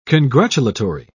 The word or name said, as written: congratulatory